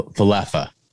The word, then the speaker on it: Falefa: The Yankees traded Gary Sanchez and Gio Urshela to the Minnesota Twins for Josh Donaldson, Isaiah Kiner, Falefa, and the guy with really big arms. – I need to know how to fucking say their names. Is it Falefa? Falefa? No. – Falefa.